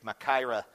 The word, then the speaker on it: Machaira: And the sword of the Spirit, which is the word of God, the principal weapon carried by Roman foot soldiers was the, I think it's Machaira. Okay, that's how you'd pronounce it in Greek, Machaira.